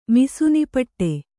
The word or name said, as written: ಮಿಸುನಿಪಟ್ಟೆ